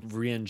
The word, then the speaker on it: Rian: R-A-A-N. – Rian Johnson.